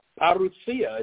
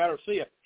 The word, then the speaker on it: parousia: Again, he's an imitator of God, and not only that, the Antichrist's arrival when he shows up, it's called a parousia is the way you say the word. You'd read and say parousia if you didn't know how to pronounce it. But parousia it means coming, and again, the Antichrist's arrival is called the same thing, the same word that's used for Christ coming.